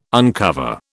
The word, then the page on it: uncover: British English